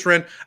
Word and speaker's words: Rennes: is it Rennes? Rennes. Just Ren.